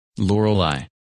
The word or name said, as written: Lorelei